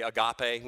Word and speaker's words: agape: The way in which God loves us, his love for us, is always represented by either the word agapao or the word agape, or want some form of that. So we oftentimes in English we say agape. We use that, that's kind of our way of pronouncing it.